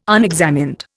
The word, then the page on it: unexamined: Indian